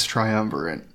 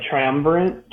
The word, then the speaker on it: triumvirate: Yeah, it's something military, something science triumvirate.